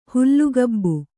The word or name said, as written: ಹುಲ್ಲುಗಾಬು